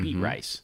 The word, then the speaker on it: Beatrice: So it was Beatrice N-E-B-R back mm-hmm. then, right? Well, and Beatrice, for non-Nebraskans, is spelled B-E-A-T-R-I-C-E, mm-hmm. beet rice.